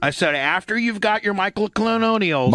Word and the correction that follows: monoclonals: Monoclonals. – I know, you know how to say it.